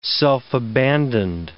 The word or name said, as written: self-abandoned